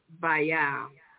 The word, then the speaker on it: Baal: Reverend Price pronounces it Baal. – Oh, really? – So I guess she's saying, yeah, instead of Baal, she pronounces both A's, like Baal.